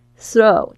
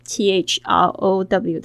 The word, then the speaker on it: throw: Throw t h r o w，特别注意一下 t h 的发音的声音，舌尖两排牙齿轻轻咬住，throw throw the tissue tissue t i s s u e tissue 纸巾或面纸 in the trash in the trash 就是丢到垃圾桶里。